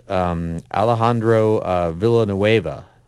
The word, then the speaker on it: Alejandro Villanueva: And that is Alejandro Villanueva. I think I'm saying that right. – Yep.